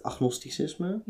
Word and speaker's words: agnosticisme: dus ik, ik denk dat ik eerder, als ik er een label aan geef, is het eerder, ehm, hoe spreek je dat uit? Agnosticisme? – Ja.